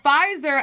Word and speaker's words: Pfizer: They did release a statement that I saw that the Pfizer vaccine, is that, I hope I'm pronouncing it right. – I think y'all gonna it's be, Pfizer or Fitz, it's Pfizer.